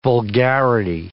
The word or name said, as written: vulgarity